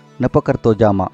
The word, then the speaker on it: nepakartojama: The Lithuanian word we are going to explore today is pronounced Nepakartojama.